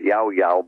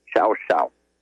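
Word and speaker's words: Llao Llao: In Spanish, that's pronounced Yao Yao, but in Argentine Spanish, it's Xiao Xiao.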